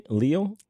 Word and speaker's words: Leal: How do you say her name? Leal? – Yeah, she was in Dreamgirls.